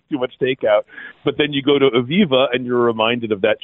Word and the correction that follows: Eviva: It is Eviva, the name of the restaurant, not Aviva, just to make sure. – No, that's right. That's right. Okay. Eviva.